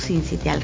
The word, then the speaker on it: sincitial: Entonces, precisamente hoy viene a hablarnos de diversos temas, pero uno de ellos es el virus sincitial, así lo dije bien. – Sincitial respiratorio. – Así.